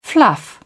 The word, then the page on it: fluff: British English